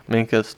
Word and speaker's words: Minkus: Does it rhyme with Stinkus? – Yeah. Minkus, Stinkus. Yes, it does.